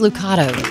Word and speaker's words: Lucado: I thought it was Lucado. Rhymes with avocado.